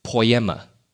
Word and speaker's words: poiema: The Greek word for have been made is pronounced poiema. Poiema. It means to create, construct, or compose.